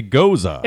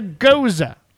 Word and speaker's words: Gose: A Gose. – It's a Gose. Now, it's a Gose. Now, it says it's got a dash Gose.